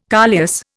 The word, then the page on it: coleus: Indian